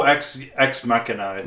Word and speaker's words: Ex Machina: I was going to actually say Machina because in Italian it would be Machina and the Italian brain in me reads it as Machina before. I think it was Ex Machina or Machina. – Whatever. My brain reads it as Machina, but I got to remember that I'm speaking English.